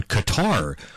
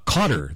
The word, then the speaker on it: Qatar: Next up on the sporting calendar today, Canada's second game at the World Cup, taking on Qatar, or Cotter this afternoon, depending upon how you want to pronounce it.